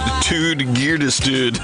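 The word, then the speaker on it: Ted Gärdestad: This is Ted Gardestad. Back in business, 1978. Back into business. Who said pronounced Tude Girdestad? That's how it's pronounced.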